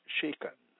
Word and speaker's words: shakan: The Hebrew word for inhabited is shakan, pronounced shaken, meaning to lodge, to settle down, abide, dwell, tabernacle, reside.